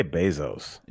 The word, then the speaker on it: Bezos: I say Bezos. – Yeah, like basil.